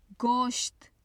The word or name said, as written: gosht